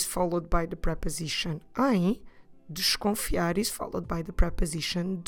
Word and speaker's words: desconfiar: D-E-S-C-O-N-F-I-A-R. Desconfiar. Notice that that first syllable, dish, we don't need to pronounce it in a very open and clear way. We don't need to say desconfiar. We can just go slightly through that syllable.